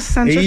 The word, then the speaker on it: Sanchez: Est-ce que tu dirais que c'est Alexis Sanchez ou Alexis Sanchez? Comment on prononce? – Genre, comme je vais pas m'interpréter. – C'est pas un ami hispanophone, mais Alexis Sanchez, comme sans la prononciation du S, c'est généralement ce que j'ai entendu.